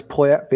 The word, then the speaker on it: Poyet: Did I hear you say his name in Uruguayan? Poyet. – Oh, is it Poyet? I don't know. – Yes, Gustavo Poyet.